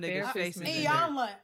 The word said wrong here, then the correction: Iyanla: I never say her name right, Now. that's one thing. No, I Iyanla, did I say it right? Yeah, I think you said it right.